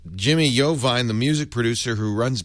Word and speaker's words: Iovine: Table says Iovine is pronounced Iovine. I-O-V-I-N-E.